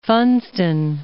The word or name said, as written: Funston